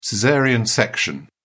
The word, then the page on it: caesarean: British English